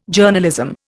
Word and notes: Journalism: Indian

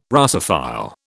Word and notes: Russophile: British English

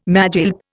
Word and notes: magilp: Indian

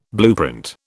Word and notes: blueprint: British English

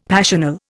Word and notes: passional: Indian

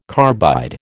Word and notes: carbide: American English

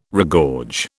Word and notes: regorge: British English

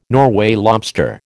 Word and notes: Norway lobster: American English

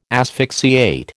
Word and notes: asphyxiate: American English

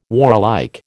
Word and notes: warlike: American English

